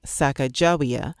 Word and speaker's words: Sacagawea: And many Shoshone people pronounce her name Sakajawea, maintaining that the name means boat launcher. I'm going to use the Shoshone pronunciation.